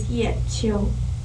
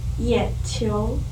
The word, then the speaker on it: Yechi: Everybody knows that my name is Rachel, but my legal name is Yechi. Many people pronounce it wrong, like Yequi or Yechi or whatever. No American people can pronounce it correctly because the syllable does not exist in English.